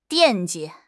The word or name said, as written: 惦记